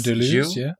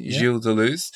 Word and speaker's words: Gilles Deleuze: Spelt G-I-L-L-E-S. – Gilles Deleuze, Gilles Deleuze.